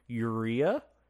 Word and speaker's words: urea: And something called, if I'm pronouncing it correctly, it's four letters, so hopefully I am, urea, U-R-E-A. Urea. – That sounds about right.